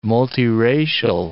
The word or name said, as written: multiracial